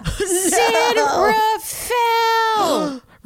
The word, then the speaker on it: San Rafael: San Rafael." Remember, it's pronounced San Rafael for the punchline to really land.